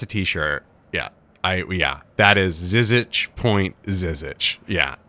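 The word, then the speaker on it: Žižić: Zizic, excuse me. I'm finally figured out how to pronounce his name correctly. – Zizic.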